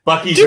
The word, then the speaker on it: Buc-ee's: It says Butte. I almost did it again. Buc-ee's are bust. – What the hell? You're literally because it's spelled Buc-ee.